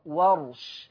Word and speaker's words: ورش: بفتح الواو وسكون الراء، ورش.